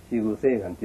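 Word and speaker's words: 四弘誓願: 四つの広い、近いと願いって書いてね、四宮聖願って読むんだけど。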